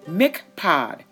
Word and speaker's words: Miphkad: and it is the gate Miphkad. All right, so the word Miphkad, and let me spell that for you M is in Mary, I P H K A D.